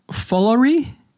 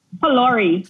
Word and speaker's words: Pholourie: I might say it wrong, but uh, foolery. – Pholourie.